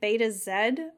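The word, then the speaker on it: Betazed: Also, I saw from the subtitles, it's like Beta Zed. I've always said Betazoid. – I think I've always said it wrong.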